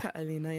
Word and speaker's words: Ka'Alina: Oh, fuck, how do you say this? Kaelana? – Yeah. – Kaelana, yeah. – Kaelana? – Kaelana, yep, there it is, Kaelana.